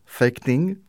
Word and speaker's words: fäktning: On va commencer par l'escrime, qui se prononce fäktning. – Fäktning.